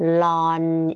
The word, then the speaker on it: ln: ห้ามอ่านว่า in นะอ่านว่า ln นะคะ